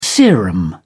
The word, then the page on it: serum: British English